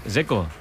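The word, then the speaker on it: Džeko: Porque Dzeko. DZ, ¿cómo se pronuncia? – Dzeko. Bruno Dzeko. Dice que es Dzeko. – Como tirando a J, Dzeko o algo así.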